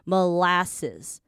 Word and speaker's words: molasses: 好，molasses 这个我来强调一下，是这样子哦，molasses。好，这边所有的 s 都是 s，不会有 z 的发音，这边稍微注意一下就好了。